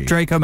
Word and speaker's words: Draco: Am I saying that correctly? – Yes, Draco Malfoy. – You guys got to remember, I'm from the Rocky era where there was Ivan Drago, something in Draco, but it was Draco.